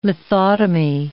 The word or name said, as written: lithotomy